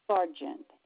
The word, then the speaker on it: Sargent: P-A-T-R-I-C-I-A. Patricia. – Oh. P-A-T-R-I-C-I-A. – P-A-T-R-I-C-I-A. – Yes, ma'am. And your last name? – Sargent. S-A-R-G-E-N-T. – And that's S like Sam? – Sam, yes. S-A-R-G-E-N-T.